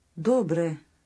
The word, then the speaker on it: добре: Добре.